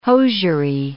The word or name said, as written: hosiery